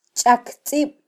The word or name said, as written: chaktzib